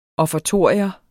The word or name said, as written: offertorium